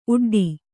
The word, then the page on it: ಊಡಿಡು: ūḍiḍu